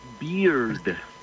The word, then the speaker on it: beard: Porque barba en inglés se pronuncia beard, termina con D al final.